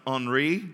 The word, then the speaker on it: Henri: How many of you are familiar with a guy by the name of, it's actually pronounced Henri, which is Henry in French, but Henri Nouwen.